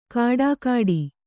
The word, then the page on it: ಖಡಾಖಡಿ: kannada